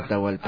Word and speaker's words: Atahualpa: Atau y Alpa, algo así. Se pronuncia Atahualpa.